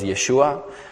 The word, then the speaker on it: Yeshu: Actually, his name in Israel, in Hebrew, as we know, is Yeshua, but they pronounce it Yeshu. They drop the ayin at the end, and it stands for Imach Shmo V'Zichro, which means in English, may his name and memory be erased.